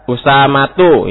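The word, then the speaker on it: Usamatu (أسامةُ): Usamatun, Usamatun. Nah, ini juga salah. Ya, Usamatu. Usamatu. Nah, ini. Sehingga bukan hanya memberi nama, tapi harus tahu juga cara baca nama anaknya ini. Kalau di akhir marbutoh, maka tidak bertanwin.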